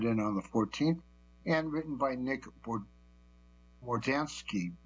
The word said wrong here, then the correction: Mordowanec: I think it's pronounced Mordowanec.